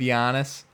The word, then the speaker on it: Giannis: I'm aware that that starts with a G. But Y was hard, and it sounds like a Y when you say his name. So we're going to go with Giannis.